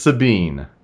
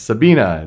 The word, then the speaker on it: Sabine: Everyone says Sabine. Sabine. This is the first name of my mother. So I've grown up my entire life, you know, it's Sabina. It's the German pronunciation. It's a German name.